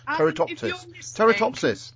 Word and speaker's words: Turritopsis: It looks like turritoposis. No, turritopsis.